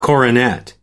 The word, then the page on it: coronet: American English